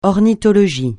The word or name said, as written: ornithologie